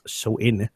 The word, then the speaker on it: Samhain: So yes, so Halloween dating back to the Celtic festival of Samhain, and that's actually spelled Samhain, but pronounced Samhain.